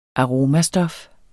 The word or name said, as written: aromastof